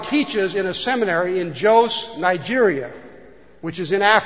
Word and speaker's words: Jos: but there's a capital in Nigeria called Jos, J-O-S, or Jos. How do you say the word? – Jos. – Okay, Jos, Nigeria.